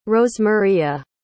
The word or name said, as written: rosemaria